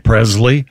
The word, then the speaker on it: Presley: why are you mispronouncing yeah. Presley? – What did I say? – Presley. – It's a hard Z.